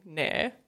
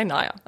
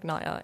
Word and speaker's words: Nair: Um, but yeah, I made like a decision after high school I'm not saying Nair ever again to anyone. So now I say now I say Naya, and I spell it to people if they like have to take my name or something like Naya N A I R because it's like yeah, it, it's that's my name.